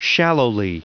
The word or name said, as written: shallowly